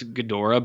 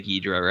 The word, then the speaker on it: Ghidorah: I like saying Ghidra. I know it's Ghidorah, but some people say Ghidra, right? – Yeah, that probably is like maybe closer to the Japanese pronunciation, but Ghidorah.